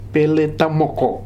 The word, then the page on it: Peletamoko: Futuna